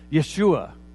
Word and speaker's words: Yeshua: Is we've taken the Hebrew word, as we've translated scriptures over the years, YHWH, and then the Hebrews pronounced the word when they had to pronounce something, Yeshua. And so probably on his earthly life, Jesus by his parents would have been called Yeshua, because that's the Hebrew way of saying that name that we call Jesus.